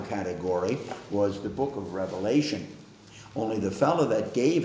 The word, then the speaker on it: Revelation: There's no S. It's only one revelation.